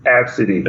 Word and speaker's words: Abcde: Pronounced it Ab City.